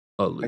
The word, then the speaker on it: Eleusis: Eleusis? – I think you have the pronunciation correct.